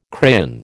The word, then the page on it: crayon: American English